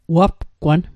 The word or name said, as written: wapgwan